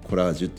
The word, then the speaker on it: collage: でコラージュ